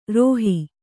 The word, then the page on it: ರುಹೆ: Kannada